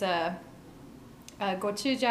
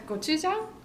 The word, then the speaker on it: gochujang: And then the other one is gochujang.